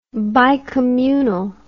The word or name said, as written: bicommunal